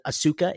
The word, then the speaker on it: Asuka: Asuka is her, I guess he mispronounced it, Asuka is her name.